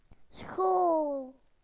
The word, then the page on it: school: British English